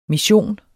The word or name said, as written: mission